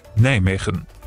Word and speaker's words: Nijmegen: Richtig ausgesprochen, die Niederländer sagen so. – Nijmegen.